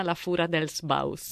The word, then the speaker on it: La Fura dels Baus: Fondamentale è stato il tocco, adesso dimmi se lo pronuncio nel modo giusto, della compagnia catalana La Fura dels Baus.